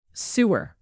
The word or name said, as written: sewer